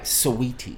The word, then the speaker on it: Saweetie: Okay, you got to pronounce it right. Saweetie.